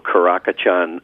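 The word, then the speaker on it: Karakachan: Is that, am I pronouncing it right? – Yeah, Patrick, thank you so much for not letting me forget our delightful Caracachan guardian dog.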